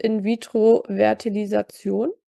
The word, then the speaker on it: In-Vitro-Fertilisation: Aber IVF heißt In-Vitro-Fertilisation. Richtig? Habe ich das gut ausgesprochen? – Sehr gut, kriegst eine 1 plus mit Sternchen.